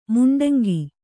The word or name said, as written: ಮುಂಡಂಗಿ